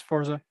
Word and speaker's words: Forza: There is no T in it. It's Forza. – It is pronounced Forza, not Forza. The developers have done this as well. It is Forza. They have said it is Forza. You go listen to any video, they say Forza, not Forza.